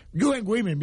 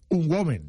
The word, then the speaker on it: UN Women: UN Women, bien pronunciado. UN sí, Women. Carlos Bosch dice un woman.